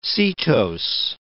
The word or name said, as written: setose